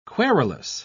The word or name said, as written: querulous